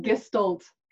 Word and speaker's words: Gestalt: Is that how I pronounced it properly? Gestalt? – Yes, you have. Gestalt.